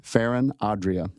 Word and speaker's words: Ferran Adrià: Ferran Adrià.